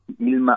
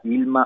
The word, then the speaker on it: Hilma: Y acá les quería recomendar a los oyentes que busquen a Ilma af Klint, una artista súper interesante. – ¿Cómo se escribe? – Ilma. – Ilma, ¿cómo suena? – Sí, H-I-L-M-A.